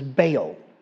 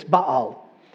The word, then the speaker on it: Baal: All my life, I was told this God's name was Baal. I have since found out by people that really know the languages, it's Baal.